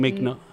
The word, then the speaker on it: McNulty: Is your how do you say your last name? – McNulty. – Okay, see, mm-hmm. I got it right.